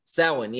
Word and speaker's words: Samhain: Samhain, yes.